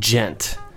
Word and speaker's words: Ghent: His name is AJ Gent. – Oh, by the way, and if just look, it's spelled AJ and then GHENT.